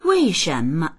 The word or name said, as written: wei4shen2me